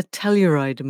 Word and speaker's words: Telluride: including the Telluride Mushroom Festival.